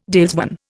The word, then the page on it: dalesman: Indian